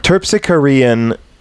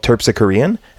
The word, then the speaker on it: Terpsichorean: Terpsichorean to me has something to do with a bird of prey.